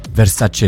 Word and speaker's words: Versace: It's like Versace. Versace is pronounced Versace.